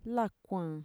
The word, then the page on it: lakuan: Mixtec